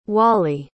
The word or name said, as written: wallie